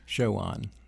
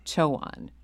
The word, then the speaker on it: Chowan: And please do correct my pronunciation on those two colleges, Roanoke. – Chowan. – Chowan. Thank you.